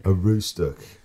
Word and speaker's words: Aroostook: I believe it's pronounced Aroostook. county to have a dedicated courthouse therapy dog. – A roostook.